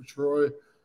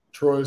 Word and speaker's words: Troye: It's my full name, Christopher Troye. Troye is spelled T-R-O-Y-E.